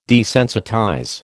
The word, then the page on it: desensitize: American English